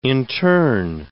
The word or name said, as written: intern